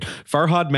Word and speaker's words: Farhad: And Farod. God, I always pronounce his name wrong because, again, I only just read it. Farhad Manju, who is a tech writer who I actually really like.